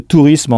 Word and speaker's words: tourism: T-O-U-R-I-S-M. Donc là encore, pas de E à la fin. Mais faites bien attention à la prononciation. En effet, on entend une syllabe de plus qu'en français, puisque la terminaison ISM se prononce ism.